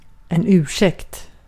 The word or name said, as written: defence